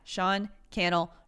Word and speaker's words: Cannell: and Sean Cannell at Sean Cannell, rhymes with channel, over on Instagram so we can see your favorite episode so far.